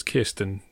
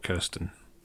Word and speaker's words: Kirsten: So it's Kirsten, not Kirsten.